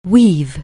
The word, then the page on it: weave: anglais